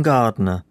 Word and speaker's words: Gardener: Ask for Eamon Gardner. Shall I spell that for you? – Yes, please. – That's Eamon, as in E-A-M-O-N-N, and my surname is spelt G-A-R-D-E-N-E-R.